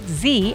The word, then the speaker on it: Zee: Let me know at Z. And of course, people don't realize my name is spelled Z-E-E.